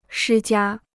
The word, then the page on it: 施加: shī jiā